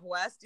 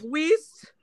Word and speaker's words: West: People have even pronounced my name wrong. And how hard is it to fuck up West? You know what I mean? – Like, yeah. – How? And they're like, Weast. It's like, no, that's not my fucking last name.